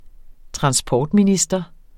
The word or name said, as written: transportminister